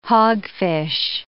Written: hogfish

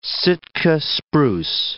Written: Sitka spruce

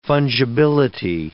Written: fungibility

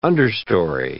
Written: understory

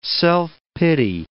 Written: self-pity

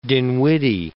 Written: Dinwiddie

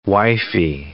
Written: wifey

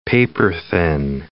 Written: paper-thin

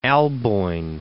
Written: Alboin

